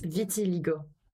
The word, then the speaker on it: vitiligo: Now, I know that you've been very open about this, and I forgive me, I can't pronounce it probably correctly, but is it Vertilis? Vertila? – It's vitiligo.